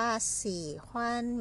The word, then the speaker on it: 喜欢: สี่ขวัญ